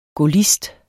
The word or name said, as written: gaullist